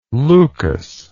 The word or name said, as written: leukas